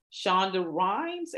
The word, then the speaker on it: Shonda Rhimes: I had just finished reading Shonda Rhimes.